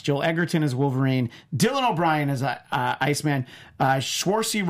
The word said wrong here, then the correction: Saoirse: Ronan, I know I said her name wrong, as Jean Grey. That's his X-Men. So I like that he okay. cast all those people. – Yeah. – So what was that, Brie? – Saoirse Ronan.